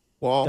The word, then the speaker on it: Woll: I also hate to do this, but I think it's Wall, not Wool. – All right, Wool. Wall. – W-O-L-L? – Yeah, okay. Pronounced more like Wall.